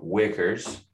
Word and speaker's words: Weichers: I am with Hayden Weichers.